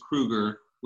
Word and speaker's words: Krueger: So my name is Joseph, spelled with an F, like Freddy, and my last name is Krueger, like Freddy.